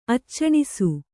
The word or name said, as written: ಅಚ್ಚಣಿಸು